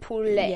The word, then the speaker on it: poulet: I would think it's like poulet. Yeah. Poulet. Maybe no T at the end. – There's no T. It's like a silent T.